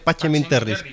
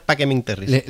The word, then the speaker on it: Pacem in Terris: Depende del tipo de pronunciación, porque en la pronunciación eclesiástica sería pacem in terris, y la clásica romana es pacem in terris.